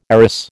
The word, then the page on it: arras: American English